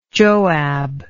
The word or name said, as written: joab